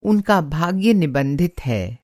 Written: निबंधित

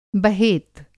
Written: बहेत

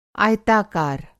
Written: आयताकार

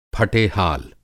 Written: फटेहाल

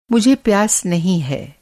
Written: प्यास

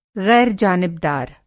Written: ग़ैर जनिबदार

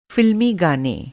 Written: फ़िल्मी

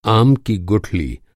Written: गुठली

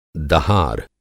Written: दह

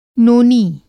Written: नोनी

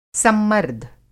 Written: सम्मर्द

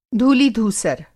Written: धूलि-धूसर

